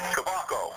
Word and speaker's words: Kubacko: Kubaco. – How do you spell that? – K-U-B-A-C-K-O.